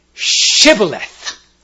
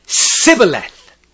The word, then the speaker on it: Shibboleth: If he said nay, then they said to him, "Say now, Shibboleth." And he said, "Sibboleth." For he could not frame to pronounce it right.